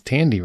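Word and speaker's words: Thandie: Yeah, at one point I said Thandy and I felt horrible. And then somebody's like, you know, it's Tandy, right? I'm like, is it?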